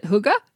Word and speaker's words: hygge: So, you know, we talked to the podcast before about the idea of I think we pronounce it hygge. Is that right? – Mm-hmm. Yes.